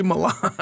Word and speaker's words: Milan: Kind of like Milan here in Michigan. Yeah. Everybody pronounces it Milan. – Yeah. – Yeah. – No, yeah. – It's clearly Milan.